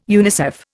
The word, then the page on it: UNICEF: Indian